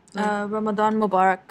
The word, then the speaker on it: Ramadan Mubarak: Ramadan Mubarak. – Mubarak. – Actually, I said it in English. I'm sorry. – Say it in Arabic. – You know. – Say it. – Ramadan Mubarak.